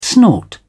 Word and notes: snort: English; British English

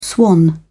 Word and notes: swan: British English

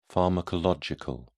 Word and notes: pharmacological: British English

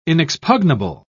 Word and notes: inexpugnable: British English

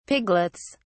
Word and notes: piglets: British English

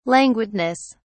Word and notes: languidness: British English

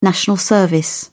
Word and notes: national service: British English

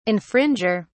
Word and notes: infringer: British English

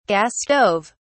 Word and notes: gas stove: British English

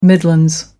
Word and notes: midlands: American English

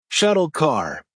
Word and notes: shuttle car: American English

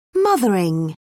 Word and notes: mothering: British English

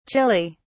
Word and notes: Chile: British English

